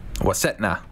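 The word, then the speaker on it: Waskatenau: Is it Wasetnu? Is that how you say it? – Wasetna.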